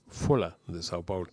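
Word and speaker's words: Folha de São Paulo: pronunciado en portugués como Folha de Sao Paulo.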